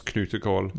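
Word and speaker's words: knutekål: In Norwegian, it's knutkål.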